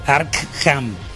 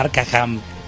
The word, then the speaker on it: Arkham: También Arkham, se dice Arkham, no Arkham. Le aumenta una sílaba.